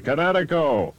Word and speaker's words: Kinetico: Everybody knows that Kinetico is spelled K-I-N-E-T-I-C-O. – I thought the instructor said Connecticut, not the East Coast. – Son, you should have remembered kinetic as in kinetic energy with an O on the end. Kinetico. – Kinetico. Spell it K-I-N-E-T-I-C-O.